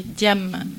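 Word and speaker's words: Jàmm: Au-delà d'un festival, je voulais parler d'une association qui s'appelle Diam Diffusion, que je devrais prononcer Diam Diffusion, puisque ça s'écrit J-A, accent grave, 2-M.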